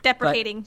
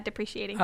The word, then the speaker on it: deprecating: Deprecating, but, not depreciating. – Oh, thank you. Thank you, deprecating.